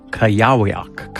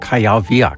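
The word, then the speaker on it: Kujawiak: The Mazurka, the Krakowiak, the Oborek, and the Kajawiak. Kajawiak, excuse me.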